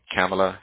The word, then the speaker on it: Kamala: Tell me how you pronounce her name again. – Kamala Harris. – Kamala. – Oh, Kamala. Man.